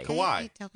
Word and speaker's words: Kawhi: It's K-A-W-H-I. – Kawhi. – Kawhi, right? – Kawhi. – That's how Bill Simmons says it.